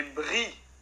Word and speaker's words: brie: É, a pronúncia correta do, do queijo é brie.